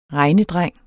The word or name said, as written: regnedreng